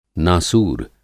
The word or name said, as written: नासूर